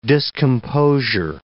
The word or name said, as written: discomposure